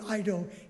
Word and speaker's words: eido: And it's the Greek word eido, E-I-D-O, it's a silent E, eido.